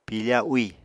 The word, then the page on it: piifa úì: Apatani